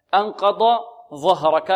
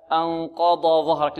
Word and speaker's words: أنقض ظهرك: بل ينطق الحرف الأول ضاد والحرف الثاني ماذا؟ ظاء، هكذا أنقض ظهرك.